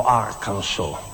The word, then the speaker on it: Arkansas: Dopo dico anche, al posto di Arkansas, nella canzone dirò Arkansas, perché 2 anni fa mi hanno detto che si pronuncia così e non è da male, non è da poco, voglio dire.